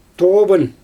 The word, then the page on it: tooban: Xyzyl